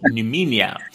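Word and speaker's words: Noumenia: So I'm probably going to butcher this pronunciation, but tell me more about Neumenia, the ancient Greek new moon manifestation ritual. – That was very like correct the way you pronounced it. It's called Neumenia.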